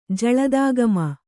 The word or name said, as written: ಜಲದಾಗಮ